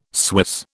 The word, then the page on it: Swiss: British English